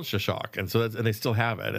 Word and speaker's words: Xoxoc: And you have a whole program, a project, the Shashak Project, which is spelled exactly as it sounds. Yeah. Shashak. X-O-X-O-C.